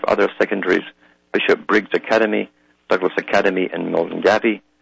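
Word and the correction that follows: Milngavie: Mulgai, actually, they pronounce it, Kirkintilloch High and Bishop Briggs Schools, St. Ninian's High and Turnbull High will also get the scheme.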